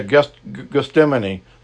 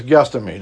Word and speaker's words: Gethsemane: Some say Gethsemane, but it's Gethsemane. That's how you pronounce it.